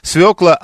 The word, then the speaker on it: свекла: Владимир Свиридов настаивает, что правильно говорить свекла, а Виталий из Филей никак не может понять, казалось бы, как от ВТО зависит картошка или морковка, если ее выращивают в Пензе.